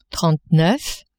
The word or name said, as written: trente-neuf